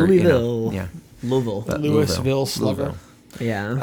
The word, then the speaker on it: Louisville: I brought you the Louisville Pale, uh, and it's spelled, I should say, L O O hyphen a hyphen V U H L. Yeah. So you, you kind of have this phonetic pronunciation of Louisville that I never got before I knew people from there.